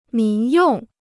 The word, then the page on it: 民用: mín yòng